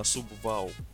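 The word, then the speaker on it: Subway: и на самом деле правильно по-английски произносить именно субвау, а мы все не знали.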